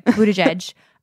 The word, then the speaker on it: Buttigieg: Peter Buttigieg. I hope I'm saying that right. – He says Buttigieg. So yes, you said it right. – Okay, good. Buttigieg.